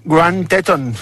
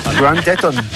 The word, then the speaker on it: Grand Teton: Grand Teton. Grand Teton.